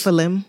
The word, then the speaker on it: Nephilim: You have the Nephilim. I don't know how to pronounce it. There, the descendants. – Nephilim.